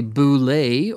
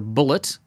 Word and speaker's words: Bulette: Uh, the Bulette or Bullet, depending how you want to pronounce it.